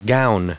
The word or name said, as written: gown